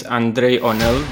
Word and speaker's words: Andrei Onel: So my name is Andrei Onel. That's how you actually pronounce it in my native language.